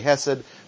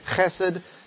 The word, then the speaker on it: chesed: not say chesed, but you want to say chesed, then you'll have the Hebrew somewhat correctly pronounced.